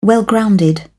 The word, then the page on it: well grounded: British English